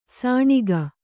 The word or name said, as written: ಸಾಣಿಗ